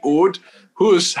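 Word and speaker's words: Auld Hoose: So, and there's a pub down there called the Oud Hoos. I know we're going to get some unusual Scottish words, but they spell it, Diane, A-U-L-D, Oud Hoos, H-O-O-S-E.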